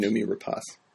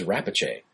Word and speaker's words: Noomi Rapace: Noomi Rapace. – Oh, is that how she? – Yeah. – Why did I think it was Rapace? – I'm not sure.